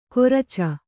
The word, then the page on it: ಕೊರಚ: koraca